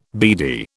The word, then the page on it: beady: British English